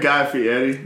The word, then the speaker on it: Fieri: Very Guy Fieri. – Yeah, very much like shaved head. – Yeah, I pronounced it right. – I know you did.